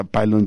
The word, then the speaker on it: Pylon: Sin embargo, Beto González, nuestro gran hombre en los controles, me dijo, falta el pilón, y tiene mucha razón, falta una banda que así se escribe, pilón, aunque se pronuncia pylon,